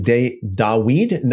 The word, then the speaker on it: Dawid: Do you say King Dawid? That would be the more of the correct way to pronounce it, right? Dawid. I say David most of the time, or almost all the time, just because that is what people.